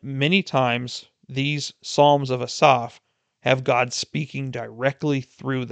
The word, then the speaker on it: Asaph: Psalm 50 is written by a man named Asaph, or you might have been pronouncing it Asaph your whole life, much like it was for me. But looking it up in how the Hebrew name is spoken, it's actually pronounced Asaph.